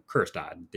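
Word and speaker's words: Kjerstad: So you're looking at guys who aren't, you know, Heston, I always say his name wrong, Jurstad, right? No, Kerstad. The J is silent.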